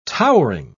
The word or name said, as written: towering